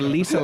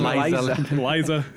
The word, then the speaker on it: Lisa: Are you talking about a different comedian or Lisa Lampanelli? You keep saying Liza. – It's a comedy cruise, okay? They say her name wrong.